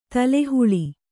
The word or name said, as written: ತಳಹಳ